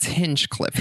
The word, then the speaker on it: Hinchcliffe: Do I say it right? Hinchcliffe? – No. It's Hinchcliffe. – Hin. Okay, I always say hitch. I put a T on it.